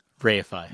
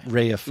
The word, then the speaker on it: reify: Rarefy. How do you say that? – Reify. – Reify.